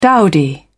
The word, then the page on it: dowdy: British English